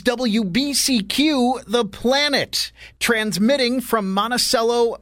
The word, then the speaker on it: Monticello: They say Monticello. That's how you say it. However, our new listeners in Charlottesville, Virginia, will be cringing because they know it's Monticello.